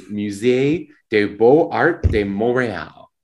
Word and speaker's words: Musée des Beaux-Arts de Montréal: I can try the French pronunciation, which is Musée des Beaux-Arts de Montréal. – Nailed it.